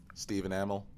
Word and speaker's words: Stephen Amell: Stephen Amell? – Stephen Amell. – Is it Stephen Amell? – Mm-hmm.